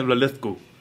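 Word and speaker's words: Linlithgow: Αλλά μου έλεγε, ας πούμε, ότι υπάρχει μια περιοχή που λέγεται Lilithgow. Κάπως έτσι. – Lilithgow, ναι. – Lilithgow. Ο τρόπος μου λέει που το προφέρουν είναι... αδιανόητος, ρε. Δεν μπορείς να αντιληφθείς ότι αυτό που διαβάζεις το λένε έτσι. Ναι, βλαλέθκου.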